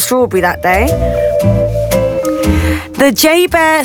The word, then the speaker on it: JBER: The bear was caught on camera entering the Exchange shopping mall on the base, right, which is commonly known by the acronym J-B-E-R, pronounced J-Bear. Yeah, it's that ironic.